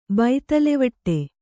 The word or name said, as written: ಬೈತಲೆವಟ್ಟೆ